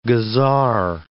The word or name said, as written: gazar